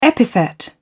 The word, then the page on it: epithet: British English